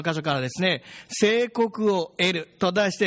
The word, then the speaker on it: 正鵠: 主法を見ていただくとですね、ものすごい難しい漢字が書いてあって、どうやって読むんだろうなって思うかもしれませんが、聖国と読むんですが、聖国というのは、弓道の的のことを言うわけですね。